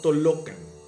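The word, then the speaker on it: Tollocan: Y ojo señores, para los que me vayan a querer corregir, nahuatlismo, la doble L en Nahuatl suena L, por lo que se pronuncia Paseo Tolocan dijo ya, me voy a parar aquí y voy a hacer del baño.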